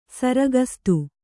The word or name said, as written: ಸರಗಸ್ತು